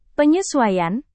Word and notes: penyesuaian: Indonesian

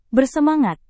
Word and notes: bersemangat: Indonesian

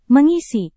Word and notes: mengisi: Indonesian

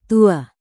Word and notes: tua: indonesian

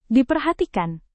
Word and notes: diperhatikan: indonesian

